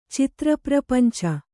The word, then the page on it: ಚಿತ್ರಪ್ರಪಂಚ: citra prapanca